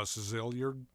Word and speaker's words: Szilard: Szilard.